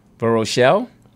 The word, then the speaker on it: Verochelle: V-E-R-O-C-H-E-L-L-E. Two L's. V-E-R-O. C-H-E-L-L-E. How would you say that? – V-E-R-O. – Verochelle? – Verochelle. – Verochelle. Bloom with blossoms.